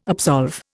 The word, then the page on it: absolve: Indian